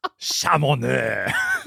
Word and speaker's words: Chamonix: Twice have you mispronounced C-H-A-M-O-N-I-X. – Chamonix. – I don't know. – I've tweeted this to you, telling you how to pronounce it, and you saw it. It's Chamonix.